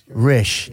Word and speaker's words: Rish: I always remember the first time you said it rhymes with fish. Rish.